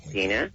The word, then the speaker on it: Dina: Dina.